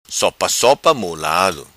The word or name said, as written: Sopa Sopa Mulado